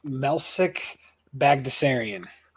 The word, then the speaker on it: Melsik Baghdasaryan: Prelims, the only person I like straight up, I like M- Melsik Baghdasaryan. That's how you say it. That is how you say it. Tell me otherwise.